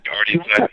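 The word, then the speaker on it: Ardian Syaf: Ardian Siaf, yeah.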